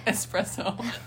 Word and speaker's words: espresso: Espresso. Fuck. There's no X. – Yeah. I knew that sounded wrong. – A lot of people say it like that. It's kind of like saying, like, especially and especially. People put, there's an X in, there's no X in especially.